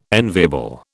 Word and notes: enviable: British English

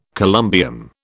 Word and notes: columbium: British English

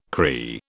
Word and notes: Cree: British English

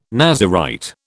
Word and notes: Nazirite: British English

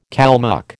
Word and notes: Kalmuck: American English

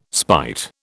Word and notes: spite: British English

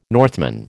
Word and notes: Northman: American English

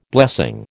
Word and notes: blessing: American English

